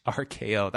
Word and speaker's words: Arcaioh: It's spelled A-R-C-A-I-O-H. RKO.